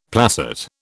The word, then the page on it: placet: British English